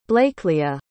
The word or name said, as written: blaecleah